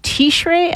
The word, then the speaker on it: Tishrei: Commandment 324, to blow the shofar on the 10th of Tishrei. I'm not sure how to pronounce that. – You got it.